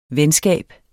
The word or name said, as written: venskab